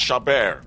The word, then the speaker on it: Chabert: It's pronounced Chabert.